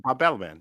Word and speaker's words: Bob Balaban: Bob Balaban.